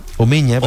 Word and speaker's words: Omegna: Uno de ellos se llama Ítalo Omaña, Omeña, se escribe Omegna, pero la G con la N e Ñ, Omeña, que es uno de los asesores además del Partido Nacional Libertario, ¿ya?